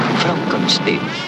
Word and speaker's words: Frankenstein: Frankenstein. ¿Me toma el pelo? No, se pronuncia Frankenstein. Usted debe de ser Igor. – No, se pronuncia Igor.